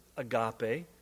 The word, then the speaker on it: agape: He calls this most excellent way of living agape. We often pronounce it agape, and so will I, since that's what you're familiar with.